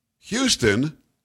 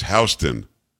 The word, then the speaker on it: Houston: In New York, Houston isn't Houston. It's Houston. Just is.